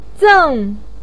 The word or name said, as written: zeng4